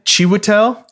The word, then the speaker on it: Chiwetel: Chiwetel. – Ooh, dang it. Say it. Mufasa. Mufasa. Chiwetel. This is how I've heard it pronounced, so sorry if it's incorrect. But Chiwetel Ejiofor.